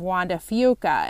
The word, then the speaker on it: Juan de Fuca: The way we understand it, many locals prefer to pronounce this Strait of Juan de Fuca, and for many years I did too. Others who we've talked to acknowledge that the words are Spanish, therefore the proper pronunciation should be using Spanish pronunciation.